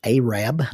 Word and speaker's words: Arab: It is pronounced Arab, not Arab. Arab, Alabama. It was supposed to be Arad, Alabama, A-R-A-D, and the postmaster flopped that letter over.